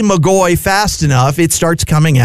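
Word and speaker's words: McGoy: But at this point, he had changed the spelling of his name to MC capital G-O-Y. So the same pronunciation, McGaughey, but an easier spelling for folks.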